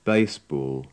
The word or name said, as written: baseball